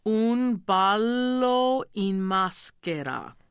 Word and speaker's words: Un ballo in maschera: I'll do it slowly. Un ballo in maschera. – Un ballo in maschera. No, that was terrible. – No, that's okay. Say maschera. – Maschera. – Now say maschera. – Maschera. – But you want the accent on the ma. Maschera. – Maschera. – That's right.